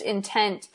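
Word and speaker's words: In10t: It's spelled I-N-10 T, but pronounced Intent.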